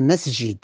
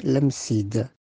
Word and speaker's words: Msid: prononciation de l'Msid, c'est le masjid. Mais comme les Français pendant le protectorat ne euh, prononçaient pas bien le masjid, c'était dit l'Msid.